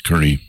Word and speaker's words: Kearney: Kearney, General Kearney, as his family pronounces it.